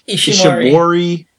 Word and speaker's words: Ishimori: The first one, Ishii. Oh, you know, I said his name wrong, didn't I? Ishimori.